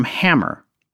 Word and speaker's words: Hamr: The water came from Hammer, or Hammer, that's spelled H A M R, where uranium was mined under the supervision of the Red Army.